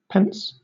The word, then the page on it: pence: British English